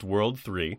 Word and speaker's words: WWW: During the events of the first game, the evil syndicate WWW, pronounced World 3.